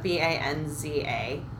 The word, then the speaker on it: Banza: And um, B A N Z A. You can say it like that, Banza.